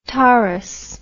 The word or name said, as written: Tauris